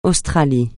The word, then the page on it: Australie: francais